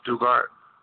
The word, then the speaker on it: Stuttgart: We're coming from Stuttgart, or Stuttgart, the way they pronounce it, Stuttgart, Germany, which is not far from Frankfurt.